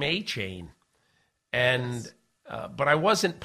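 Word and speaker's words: Achane: They called him, and he said, yeah, it's Achan.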